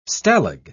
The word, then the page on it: stalag: British English